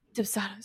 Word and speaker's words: Dubsado: Anything like Honeybook, or I know you use Dubsado. Is that how you say it? – Dubsado.